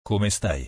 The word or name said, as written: Come stai